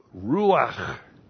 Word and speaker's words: Ruach: That's R long U A C H, Ruach.